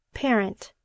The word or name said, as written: parent